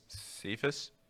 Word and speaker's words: Cephas: So you have, I'm not sure how you pronounce his first name, wide receiver, Quintez, is it Cephas? – Cephas.